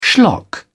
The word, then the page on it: schlock: British English